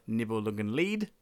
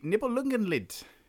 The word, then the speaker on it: Nibelungenlied: Uh, not Nibelungenlied, as I mispronounced, uh, and my lovely sister Andrea, who was guested us on episode 13. Uh, guested us. It's the Nibelungenlied, those pesky days.